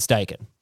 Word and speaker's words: Steichen: Well, I hope the Colts are going to be liking Shane Steichen. That is how you pronounce it.